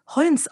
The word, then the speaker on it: Holmes: Ernest Holmes wird tatsächlich meist Holmes ausgesprochen.